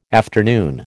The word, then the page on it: afternoon: American English